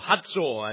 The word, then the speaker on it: Hazor: And tonight I'd like to deal with this city of Hazor, or Hazor, as the Jews call it and pronounce it.